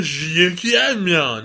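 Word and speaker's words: Zhuqiaomon: Or Battle with Zhukiamon. – Now, we know how to pronounce it now. It's Zhukiamon, because I said in the episode, and now I know.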